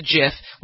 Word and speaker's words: GIF: He won the Webby Award for creating GIFs, and he said at the time that it's a Jiffy and it's a GIF like the peanut butter. But people, for some reason, when they see GIF in capital letters, they think it's a hard G.